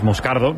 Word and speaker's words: Moscardo: Entonces, sí, como bien ha pronunciado Unai, es Moscardo,